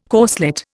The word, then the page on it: corslet: Indian